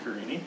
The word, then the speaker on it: Carini: That's with a K, Carini.